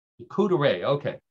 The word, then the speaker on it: charcuterie: And it's all about charcuterie. Am I pronouncing that correctly? Charcuterie? – Charcuterie. Charcuterie. Yeah. – Charcuterie? – Charcuterie. – Charcuterie, okay. So this is a world record charcuterie board.